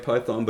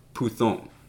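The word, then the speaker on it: puthon (πύθων): It looks like python, but it's pronounced puthon.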